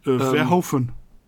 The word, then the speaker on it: Verhoeven: Verhoeven.